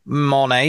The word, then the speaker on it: Monat: So a really weird side question, now that you've re-mentioned Monet, any idea why it's pronounced Monet, even though it's spelled M-O-N-A-T? I've looked it up and that looks like the correct pronunciation for it, right? – Yeah, not a clue. So some people have said Monat. – Yeah. Monat or Monar seems more likely than Monet, given that it's A-T at the end.